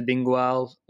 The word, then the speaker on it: Bingoal: It's Bingoal. That's how you pronounce it. Bingoal. Bingoal. Is it Bingoal? – It's Bingoal. – Bingoal, Wallonie Bruxelles. That's how you pronounce it. And yep, it's phonetically spelled that way.